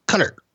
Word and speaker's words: Qatar: Every time I pronounce it Qatar, people would say it's not like guitar, it's cutter.